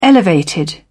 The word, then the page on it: elevated: British English